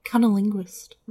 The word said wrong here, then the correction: cunnilingus: I feel like I said that wrong. Cunnilingus?